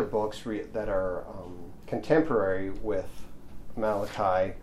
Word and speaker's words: Malachi: So this month we're going to begin the book of Malachi, and this is obviously a Hebrew name, as I've mentioned previously, that's actually pronounced Malachi, and it's derived from a Hebrew expression meaning to dispatch as a deputy or officer or a messenger.